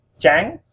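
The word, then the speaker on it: trắng: That's a TRANG, and that's with a rising tone. Chang, so we go up. Okay. And the way you pronounce that, the TR in Vietnamese is like a cross between the TR in English and the CH sound in English.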